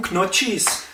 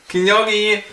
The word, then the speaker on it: Gnocchi: Fusilli und Knotschis. – Gnocchi!